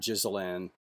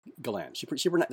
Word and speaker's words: Ghislaine: By the way, it's not Ghislaine. I've heard her name pronounced so many different ways. It's just Ghislaine, Ghislaine, it's Ghislaine. That's how she says her name, Ghislaine.